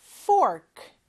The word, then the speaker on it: fork: 포크가 아니고요. fork. fork입니다. 그래서 F 발음과 R 발음이 중요하겠네요.